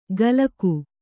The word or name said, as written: ಕಲಕು